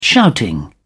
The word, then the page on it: shouting: British English